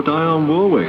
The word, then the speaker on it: Dionne Warwick: Yes, yes, that's Dionne Warwick, Dionne Warwick, or Dionne Warwick. Pronounced in many different ways by DJs all over the world.